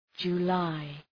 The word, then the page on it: July: dʒu:’laı